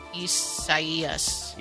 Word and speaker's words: Isaias: Well, no, but it's probably the Spanish rendering of it, which in case, and that's, if that's the case, then the I is pronounced like an E. – Isaias.